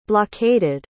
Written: blockaded

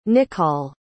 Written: nichol

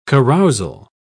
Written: carousal

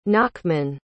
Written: nachman

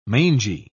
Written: mangy

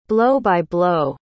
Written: blow-by-blow

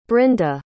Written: brynda

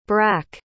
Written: brack

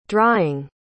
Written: drying

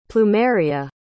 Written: plumeria

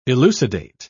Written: elucidate

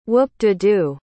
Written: whoop-de-do

